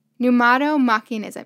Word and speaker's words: Pneumatomachianism: I will try to pronounce it. It's Pneumatomachianism. No. Did I get that right? Pneumatomachianism. Yeah, that's right.